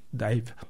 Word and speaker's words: DIIV: Et ça tombe bien, en anglais, les lettres D-I-I-V, ça se prononce aussi Dive.